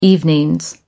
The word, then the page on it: evenings: American English